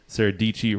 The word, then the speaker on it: Dietschy: Sarah Dietschy rhymes with peachy.